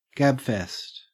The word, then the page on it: gabfest: Australian English